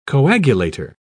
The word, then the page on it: coagulator: British English